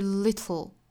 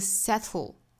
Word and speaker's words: little: 我们说到了英音的话会发成一个 d d d，典型的单词就是 little little，包括后面的这个 settle settle 这个词也是英音来说舌边爆破的发音。